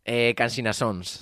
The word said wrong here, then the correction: Songs: es que lo pronuncié mal. – Eh, dije Cansina Sons. – claro, es, Songs, ¿no?